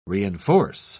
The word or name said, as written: reinforce